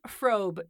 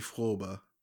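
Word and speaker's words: Fröbe: Anyway, Gert Frobe. Did I do it right? – Yeah, that's how they say it in English. In German, it would be Frobe.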